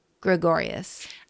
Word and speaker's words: Gregorius: His walk-up is Notorious by Notorious B.I.G. because it rhymes with Gregorius.